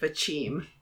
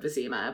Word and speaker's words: Vizima: And then the audiobook actually gets worse, because the audiobook narrator pronounces it Vachim. – Why? There is no way. Yeah. There is no way in which you could pronounce a Z as a.